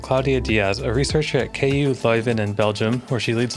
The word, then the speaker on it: KU Leuven: Today we have Claudia Diaz from KU Leuven.